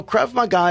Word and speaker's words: Krav Maga: So Krav Maga is an Israeli-style self-defense, which everybody in the Israeli army actually goes through.